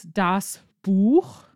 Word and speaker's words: Buch: We have the words das Buch, singular, and you have the ch sound at the end.